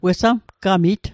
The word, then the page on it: wesamgamit: Mi'gmaq